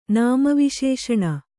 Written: ನಾಮವಿಶೇಷಣ

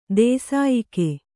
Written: ದೂಸೆಯಕ್ಕಿ

